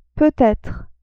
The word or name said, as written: peut-etre